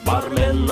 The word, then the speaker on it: бармен: Бармен, наливай, наливай. Бармен, бармен, на. – Стоп-стоп. Конечно же, правильно говорить «бармен». Не поддавайтесь своему желанию, по логике слова «супермен», поставить ударение на «е».